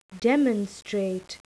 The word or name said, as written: demonstrate